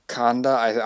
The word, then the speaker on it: Conda: Conda, I believe so. Sounds like Honda, but honestly, pretty cool name.